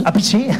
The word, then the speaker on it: Appice: Appice. – Sí, sí, y lo pronunciaban, lo vi subtitulado. – Sí, y yo tampoco sabía que se pronunciaba Appice.